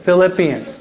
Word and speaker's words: Philippians: Ephesians, Filipinos. Did I say that right? Oh, I'm sorry. Not Filipino, but Philippine. I always mispronounce it. Okay. So it's the Philippians, not Filipinos.